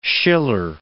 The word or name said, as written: Schiller